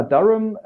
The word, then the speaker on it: Durham: Ja, du bist dann nach Durham, spricht es richtig ausgegangen? – Ja, also das kannst du aussprechen, wie du willst. Ich sage immer Durham, Darum, okay.